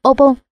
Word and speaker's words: Obon: Ở Nhật Bản một năm có 4 dịp lễ lớn, đó là Shogatsu, đọc là Shogatsu, Hikan, đọc là Hikan và Obon, đọc là Obon.